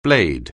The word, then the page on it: blade: anglais